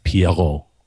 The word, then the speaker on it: Pierrot: It is being produced by Studio Perol. Is this French? Am I saying this right? – If you want to be about it, it's Pierrot. Pierrot. It means clown.